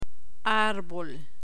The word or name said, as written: arbol